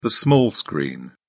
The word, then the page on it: small screen: British English